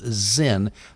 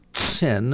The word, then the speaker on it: Zin: I'm anglicizing it. I think that I had an Israeli guy pronounced it Tsin, and so I'm sure that's how you pronounce it. But anyway, I'm going to call it the wilderness of Zin.